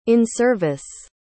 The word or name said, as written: in-service